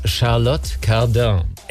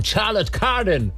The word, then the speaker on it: Charlotte Cardin: Also französisch geschrieben wäre es Charlotte Cardin. Englisch würde man es vielleicht sagen, Charlotte Cardin.